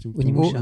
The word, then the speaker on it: Onimusha: Euh, ah, j'arrive jamais à le prononcer ce nom. Omni Nusha. tu, Onimusha. Omni, voilà.